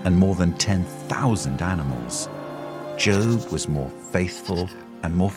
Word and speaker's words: Job: In the whole Old Testament, there was no one with a harder life than Job. By the way, Job is not a job that rhymes with cob, but Job that rhymes with robe.